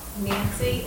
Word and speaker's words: Nancey: Nancy, and my name is spelled N-A-N-C-E-Y, has a sneaky E in there. Kinley. K-I-N-L-I-N-L-I. Yes. – Okay. – I'm Ann, with an E. Dugan, D-U-G-A-N.